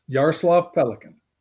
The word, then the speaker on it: Yaroslav Pelikan: Am I saying that right? – Yes, Yaroslav Pelikan.